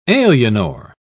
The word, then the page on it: alienor: British English